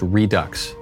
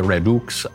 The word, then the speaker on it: Redux: And that's how you pronounce it, by the way, is Redux. No, I was pronouncing it in the French way, maybe the Latin way, Redux.